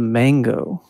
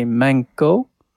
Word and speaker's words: mango: But if you pronounce it incorrectly and you say manko... – That's a vagina. – Like with a K instead of a G.